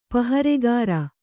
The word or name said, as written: ಪಹರೆಗಾರ